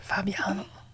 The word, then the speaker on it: Fabiano: Yeah, that's how you say it. Fabiano. If you're a hair salon, you gotta be like kind of like yeah. Fabio, but like Fabiano.